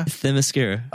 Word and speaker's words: Themyscira: Hey, okay, so this whole Thera, how do you say that name? Themyscira? – Themyscira. – Okay.